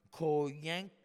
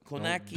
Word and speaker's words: Kownacki: Uh, he lost to uh, Adam Koyank. Ko, Ko, what, jeez. – Yeah, don't. Kownacki.